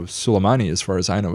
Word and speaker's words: Soleimani: Yeah, Soleimani. That's the proper pronunciation.